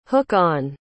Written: hook-on

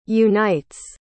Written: unites

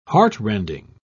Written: heartrending

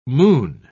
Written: moon

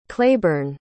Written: claybourne

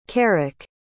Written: karik

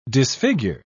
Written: disfigure

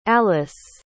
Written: alliss

